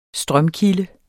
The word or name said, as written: strømkilde